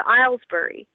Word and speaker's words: Aylesbury: I would say you're probably your largest meat bird, and it surprisingly looks a lot like a Pekin, is the Islesbury. That's spelled Aylesbury.